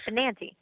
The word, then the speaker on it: Benanti: And so I was like, "That's not how you say her name. It's Benanti."